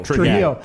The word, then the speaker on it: Trujillo: Robert Trujillo? – Trujillo.